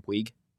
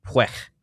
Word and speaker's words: Puig: He doesn't pronounce his last name Puig? – Really? – Yeah, it's like Puig.